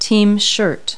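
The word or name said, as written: team shirt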